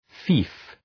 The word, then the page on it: fief: fi:f